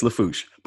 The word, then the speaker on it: Lafourche: Okay, Lafourche. – Yeah, I would have said Lafourche. I don't know. – Yeah, it's Lafourche. But the city I live in is Thibodaux, and it's spelled T-H-I-B-O-D-A-U-S.